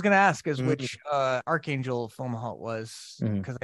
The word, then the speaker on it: Fomalhaut: Fomalhaut.